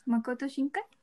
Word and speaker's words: Makoto Shinkai: Porque los finales de las películas de Ay, me ayudas con la pronunciación. Makoto Shinkai. Tal, cual.